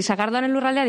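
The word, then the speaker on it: Sagardoaren Lurraldea: Formáis parte del proyecto Sagardo Arén Lurraldea, lo digo bien así. – Sí, muy bien, eso es. Sí, Sagardo Arén Lurraldea, digamos que es como una marca turística que aglutina un poquito pues todo lo que está alrededor de la sidra, ¿no?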